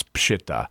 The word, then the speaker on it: Peshitta: And although you'll sometimes see it, people pronounce it Peshitta, but to be technically proper, it's Peshitta.